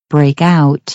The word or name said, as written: break out